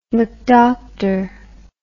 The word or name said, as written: McDoctor